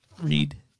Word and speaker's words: Reid: It is the one, the only Storm Feed. – Reed. It's Reed. I fucked up there.